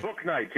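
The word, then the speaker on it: Bouknight: Booknight, James Booknight.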